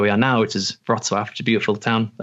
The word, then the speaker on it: Wrocław: It's called Wrocław, which is Wroclaw, but L okay. with a line through it.